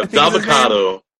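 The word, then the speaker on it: Dabba-Kato: Yeah, Dabakato. like avocado with Daba.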